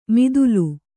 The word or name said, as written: ಮಿದುಲು